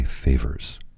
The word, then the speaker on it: Favors: Um, bassist Malachi Flavors, sorry, Malachi Favors. Wow. I just butchered his name and I feel terrible about that magnificent drummer Elvin Jones, saxophonist Steve Lacy, and the one and only irreplaceable Ray Charles.